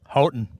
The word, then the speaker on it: Houghton: Houghton.